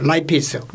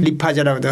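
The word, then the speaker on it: lipase: 이걸 또 유형량으로 쓸 수 있도록 해주고 그다음에 지방은 아밀레이스, 지방 이제 아밀레이스 이제 단백질이고 라이페이스, 리파제라고 돼가지고 우리 발음은 좀 발음이 좀 안 좋은 거죠.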